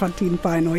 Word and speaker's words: Elefantin Paino: Kun tänään naamiot riisutaan ja koko finnische Popstar Elinora mit Elefantin Paino, habe ich das richtig gesagt? – Elefantin Paino, ja.